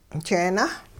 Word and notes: jeenah: hupa

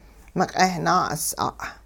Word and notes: miq'eh-naa'us'a': hupa

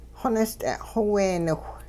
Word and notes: xonist'e'-xweeniwh: Hupa

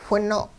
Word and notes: whino': hupa

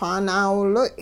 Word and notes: xwaa naawiloy': hupa

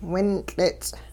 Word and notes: wintL'its': Hupa

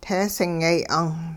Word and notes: teesingyay 'ung: Hupa

